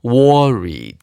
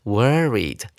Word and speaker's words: worried: 接下来我们来看第二个单词 worried 啊，不要念成 worried 啊，发音就不对喽。 worried 舌头要卷一点。